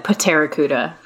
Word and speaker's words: Pterracuda: You're just like I was imagining Terra like, Earth rather than Terra with the silent P. – Yeah, sorry, I should have said Pterracuda.